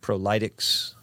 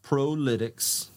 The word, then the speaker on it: Prolytics: I've heard prolytics. I've heard proletex. Hmm. um, but I, I, think there's a there's a, a, reason that the name came together, and then it kind of helps you pronounce it too. So, tell me about the the reason. So it's it's pronounced prolytics, and it's professional analytics, uh, and so.